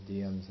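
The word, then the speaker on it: Diệm: I've said everything except the election results and Diem's action. I can't really pronounce that name. It's pronounced like with a Y.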